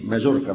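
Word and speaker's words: Majorca: اعلموا رحمكم الله أن أصلي من مدينة مايوركا أعادها الله للإسلام، وهي مدينة كبيرة، هي تكتب بالجي مايوركا بس تنطق بالواي مايوركا،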